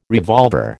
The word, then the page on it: revolver: American English